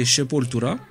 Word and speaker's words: Sepultura: alors je ne savais pas d'ailleurs qu'il fallait prononcer Sepultura.